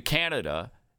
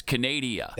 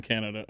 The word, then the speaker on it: Canada: when talking about KJP's mispronunciations, don't forget that she referred to Canada as Canadia.